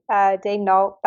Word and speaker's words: Daigneault: Well, I guess we should start here, because I actually just before the start of the podcast, how are we are pronouncing it, Mark Daigneault, like as the Thunder sent out, or are we going with the French pronunciation? – I think we're sticking with Mark Daigneault. That's how he pronounces it. That's how Presti pronounces it. So I guess we'll go with that.